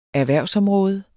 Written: erhvervsområde

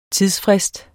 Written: tidsfrist

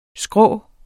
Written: skrå

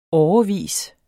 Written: årevis

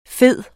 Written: fede

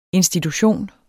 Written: institution

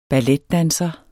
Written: BALLETDANSEREN

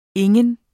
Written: ingen

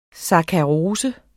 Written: sakkarose

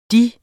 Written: deres

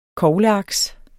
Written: kogleaks

